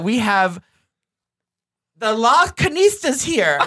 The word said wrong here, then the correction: Las Culturistas: Is that how you say it? – Here's how you say it. Las Culturistas. – Yes, Las Culturistas.